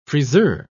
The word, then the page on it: friseur: British English